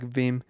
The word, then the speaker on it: Vim: I use the application Beam, uh, Vim, yeah, sorry, I said it wrong, Vim, which is spelled V I M.